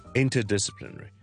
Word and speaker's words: interdisciplinary: 如果你讲嘅时候你唔将两个字都重咧，听落去咧系会有啲难嘅，因为有好多音节 inter 两个啦，disciplinary。已经有六七个 syllables 咧，你如果唔重两个咧，系有啲难嘅。听一次喎，interdisciplinary，譬如我哋话，we're running an interdisciplinary program or a course 咁样。